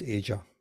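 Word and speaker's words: Aja: Asia, right? – The continent. – Like Asia, right. Okay. – The continent, yeah. – If you were an audiophile in the late 70s, you owned Asia.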